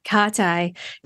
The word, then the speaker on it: Katae: KATAE, today on The Super Sensory Human.